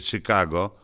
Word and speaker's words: Chicago: Czyli to musiało być w Chicago, po amerykańsku uważaj, czyta się Chicago, bo tam właśnie mieszka najwięcej górali, nawet więcej niż w Zakopanem i w Jurgowie.